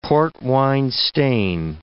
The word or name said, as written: port-wine stain